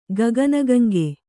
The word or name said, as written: ಗಗನಗಂಗೆ